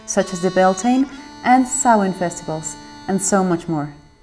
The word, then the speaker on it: Samhain: Yeah, so it's Samhain.